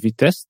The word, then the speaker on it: Vitest: That's VIT and VITest.